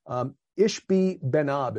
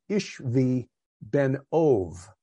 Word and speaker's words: Ishbi Benob: Ishbi Benob is how it looks in English. ISHBI-BENOB. Ishbi Benob. The actual pronunciation here would be Ishvi Benov.